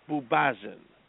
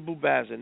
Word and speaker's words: Buksbazen: And the author's name is Victor Bubazin. Victor Bubazin. Now, the last name is spelled B-U-K-S-B-A-Z-E-N.